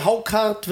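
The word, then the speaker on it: Hákarl: Harkal heißt das. Nee, Haukart wird das ausgesprochen.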